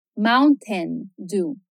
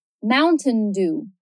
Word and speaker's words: Mountain Dew: Esse o U da escrita, tem um sonzinho como ao, mountain. Já a sílaba lá do final soa como um O longo, do, do.